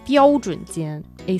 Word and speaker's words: 标准: 标准间。A standard room.